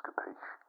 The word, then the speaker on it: capiche: Also, I was talking to the lads and, yeah, they agree it is pronounced capiche. So, we're not going to say it like how you were saying it, because that is the wrong way.